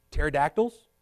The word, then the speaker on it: pterodactyl: Pterodactyls, Y'all ever heard pter- or pterodactyl, however you want to say it with the silent P there?